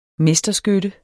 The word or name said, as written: mesterskytte